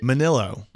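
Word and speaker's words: Minnillo: Vanessa Minilo. – Not close. – Minilo. – Not close. – Minilo. – Minilo. No, that's not it either. – How do you say it? – Manilo.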